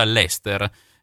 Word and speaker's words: Leicester: Prima della pubblicità, allora, mi correggo, come ci sottolinea il nostro ascoltatore, Leicester, si pronuncia Leicester per quanto riguarda la squadra da cui proviene Tavares.